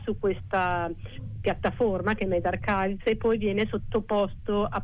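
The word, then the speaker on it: medRxiv: In effetti è Med Archives.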